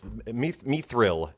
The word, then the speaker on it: Mithril: Mithril. M Y T H R I L. – Yeah, she's saying it right, Court.